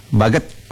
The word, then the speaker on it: Baguet: Karena saya tadi cari-cari nih, tulisannya kan Bahasa Baguet ini, bacanya apa ya Baguet ini ya? Baget, Baguet atau apa? Sampai saya cari di Translate Google, ternyata Baget bacanya begitu ya, Bu Tasya.